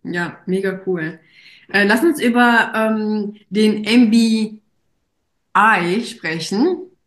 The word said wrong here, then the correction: MBAI: Habe ich es richtig ausgesprochen? – Gerne. – MBAI. – MBAI, ganz genau.